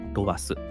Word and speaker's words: ROAS: 次に、ロアスですが、ロアスは ROAS でロアスというふうに読みます。このロアスは、リターン・オン・アドバタイジング・スペンドの各頭文字を取ってロアスと読みます。